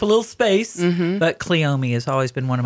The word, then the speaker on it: cleome: Some people say cleome. Mm-hmm. There's an accent on the E. So I've always said cleome.